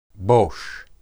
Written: bosh

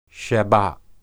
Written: shabbath

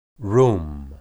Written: rum

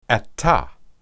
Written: atah

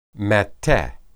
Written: matteh

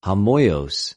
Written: ὁμοιως

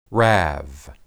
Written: rab

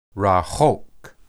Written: rahoq

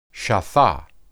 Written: shathah

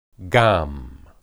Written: gam

